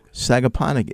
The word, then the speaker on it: Sagaponack: Sagaponack is a PGA event.